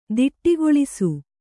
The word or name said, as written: ತಡೆಗೊಳಿಸು